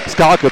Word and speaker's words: Skarke: Schnatterer geht raus, und es kommt Tim Starke mit der Nummer 38 für Marc Schnatterer, der ein ganz, ganz starkes Spiel gemacht hat, viele Offensivakzente gesetzt hat. Und Skarke, Entschuldigung, nicht Starke, sondern Skarke, ich falls ausgesprochen,